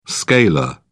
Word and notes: scalar: British English

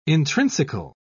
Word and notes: intrinsical: British English

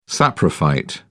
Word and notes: saprophyte: British English

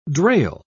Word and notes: drail: British English

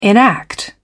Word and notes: enact: British English

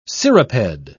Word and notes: cirriped: British English

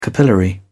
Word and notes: capillary: British English

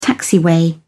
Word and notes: taxiway: British English

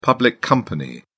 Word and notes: public company: British English

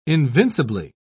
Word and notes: invincibly: British English

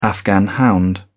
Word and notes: afghan hound: English; British English

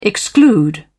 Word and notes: exclude: British English

